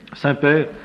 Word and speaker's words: semper: Ecclesia reformata, Saint-Père, c'est pas comme le Saint-Père, hein, ça n'a rien à voir. S-E-M-P-E-R, reformanda, comme ça se prononce.